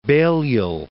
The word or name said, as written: Balliol